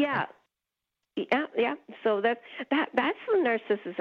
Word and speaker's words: Narcissus: Also, how do you say the name of this plant? It's a hoot trying to Google it and then finding narcissist come up every time. – Narcissus. – Okay. – Narcissus.